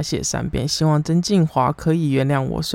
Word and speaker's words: 曾敬驊: 上集最后介绍了不良执念清除师，但是我把曾静华念成曾静业。